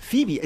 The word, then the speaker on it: Phoebe: Föb, Föbe? Guck mal drauf. Wie wird so ein Name ausgesprochen? Föbe. – Phoebe Bridges. – Phoebe. Es ist das Phoebe. – Phoebe. Das Phoebe ausgesprochen? Ja. Echt?